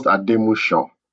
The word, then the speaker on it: Ademuson: it's pronounced Ademushan.